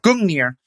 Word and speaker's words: Gungnir: And Gungnir, am I saying that right? – Gungnir.